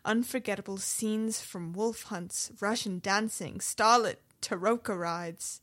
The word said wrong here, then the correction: troika: Did I say that right? Is it taroka? – No, that's the deck of fortune-telling cards from Curse of Strahd. I believe what you're looking for there is troika.